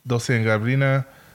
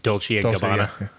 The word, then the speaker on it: Gabbana: Fucking Louis Vuitton, Dolce & Gabbana, Jimmy Choo. – You mean Dolce & Gabbana? – Gabbana. Yeah, I mispronounced it.